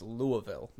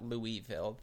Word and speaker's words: Louisville: Yeah, well, the first thing you should do to them is you should say it's Louisville, not Louisville. The in the middle is very soft.